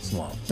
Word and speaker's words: Salem: Her name is spelled S-A-L-E-M, so you would want to say Salem, mm-hmm. – but it's pronounced Salam.